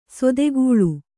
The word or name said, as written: ಸೇದೆಗಳೆ